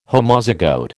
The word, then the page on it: Homozygote: American English